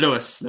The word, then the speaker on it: Lewes: Lewes, Lewes, yeah.